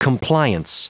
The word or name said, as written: compliance